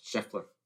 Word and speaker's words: Scheffler: Scheffler, I think. Let me make sure I'm saying this right. Scheffler. Scotty. Yes, Scheffler.